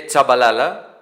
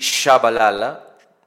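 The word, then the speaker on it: Tshabalala: ο Σίβιε Τσαμπαλάλα ή Σαμπαλάλα, όπως μπορεί να τον προφέρει κάποιος,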